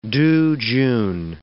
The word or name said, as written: tuchun